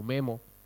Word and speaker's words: Maemo: Y el nuevo, parece que el nuevo sistema operativo de Nokia, Maemo, le dicen, o Memo, Maemo se escribe.